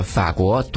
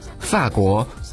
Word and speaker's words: 法国: 接下来这个呢就看起来有点高端了啊，因为在我们内地呢会把这个法国读作法国，把俄罗斯读作俄罗斯，可在台湾呢却把这个法国读作法国，把这个俄罗斯读作俄罗斯。可是呢在这个法律法官这些词语里面，法字还是读为第三声。来，我们一起来念一下啊，法国俄罗斯，有没有感觉自己嗲嗲的？